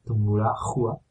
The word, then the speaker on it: Tungurahua: Tungurahua con H en el medio, Tungurahua, pero la H no se pronuncia.